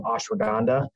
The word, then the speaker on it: ashwagandha: And we use an ingredient in the product called ashwagandha. It's a mouthful, and it took me a while to figure out how to pronounce it. But ashwagandha, it's